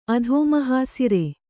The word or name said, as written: ಅಧೋಮಹಾಸಿರೆ